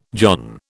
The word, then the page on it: john: British English